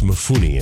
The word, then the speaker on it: Mifune: Toshiro Mifune. – Stop. – He's a hottie. – I think it's Mifune is how you pronounce his name.